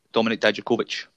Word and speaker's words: Dominic Dijakovic: How you pronounce his name again? – Dominic Dijakovic.